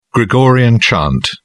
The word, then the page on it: gregorian chant: British English